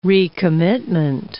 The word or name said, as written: recommitment